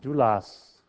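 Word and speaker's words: doulos (δοῦλος): There's a second word that's translated slave, and it's pronounced doulos.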